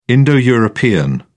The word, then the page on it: indo european: British English